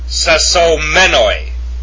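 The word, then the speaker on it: sesomenoi (σεσωσμένοι): It's pronounced sesomenoi.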